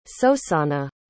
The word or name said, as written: sosanna